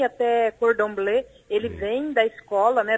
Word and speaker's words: Cordon Bleu: Será que eu vou escorregar na pronúncia? Cordon Bleu? É isso? Bom dia. – Bom dia, Gilson. É exatamente isso.